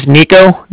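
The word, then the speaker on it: Niko: My name's Nico. That's N-I-K-O. Mm-hmm. Calling from the Bank of Cardiff, and that's spelled C-A-R-D-I-F-F, as in Frank Frank.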